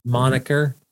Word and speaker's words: moniker: The ID, monkey, like the name of. – Yeah, that's a moniker. – Moniker. – Not monkey. Moniker. Okay. M-O-N-I-K-E-R.